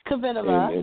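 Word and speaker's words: Kvitová: Kvitova.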